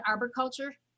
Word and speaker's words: arboriculture: Do you pronounce it arboriculture or arboriculture? – You know, I'm from Chicago, so I call it arborculture.